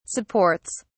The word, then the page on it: supports: British English